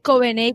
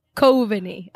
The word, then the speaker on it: Coveney: Este, yo en español le digo a la gente que es Coveney como Araguaney, pero en inglés se pronuncia Coveney, así como Cove.